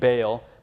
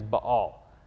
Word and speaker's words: Baal: Now, I keep saying Baal, but the correct pronunciation is Baal.